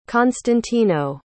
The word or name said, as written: constantino